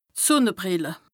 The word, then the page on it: d' Sùnnebrìlle: Bas-Rhin; Strasbourg